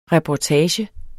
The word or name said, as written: reportage